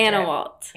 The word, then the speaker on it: Annawalt: Annawalt. – Annawalt. – Just like Fallon, it sounds. Annawalt. Spelled just like it sounds, but it trips a lot of people up.